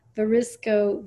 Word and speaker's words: Varisco: Yeah, it's just my name, but i my name is spelled M I C H E L after an uncle, Varisco, V A R I S C O, and dot com.